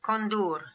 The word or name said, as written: Kondoor